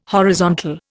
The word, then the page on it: horizontal: Indian